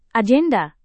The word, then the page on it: agenda: indonesian